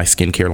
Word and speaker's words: SKNCAIR: And also I have my skincare line that I just launched last year as well. It's actually called My Skincare. It's a stylized word. Mm-hmm. Skincare is spelled SKNCAIR, mm-hmm. Like airbrush.